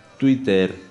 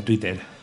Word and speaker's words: Twitter: Twitter. Es una red social, Twitter. – Es que la W no la entiendo mucho. – Bueno, se pronuncia en castellano se pronuncia como una U.